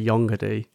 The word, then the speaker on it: Yongerdy: I don't know how you're meant to pronounce it because I've only ever seen it written down in the Troika rulebook. So yeah. I say Yongerdy. – Yeah. – And I think possibly as the first person to ever pronounce that word aloud, possibly. – I get to set how it is pronounced now and forever.